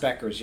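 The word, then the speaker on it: Bueckers: I think it's pronounced Beckers.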